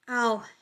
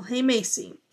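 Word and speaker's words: Algemesí: Um, but it is pronounced Aljaimacey. Oh, oh, Aljaimacey.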